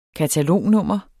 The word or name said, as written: katalognummer